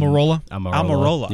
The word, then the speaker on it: Almirola: How, how do you say this last name? – Uh, um, Almirola.